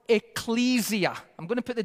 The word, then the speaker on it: ecclesia: Ecclesia. You can also pronounce it ecclesia. Ecclesia or ecclesia, kind of like tomato, tomato. Okay, even though tomato's the right one.